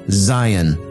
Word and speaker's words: Zayin: Zayin. Now, that can also be pronounced, and more appropriately pronounced, Zion.